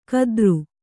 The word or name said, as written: ಕದರು